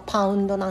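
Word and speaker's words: pound: まあ、あの英語発音するとパウンドなんですがアメリカのその重さの単位もパウンドなんですよ。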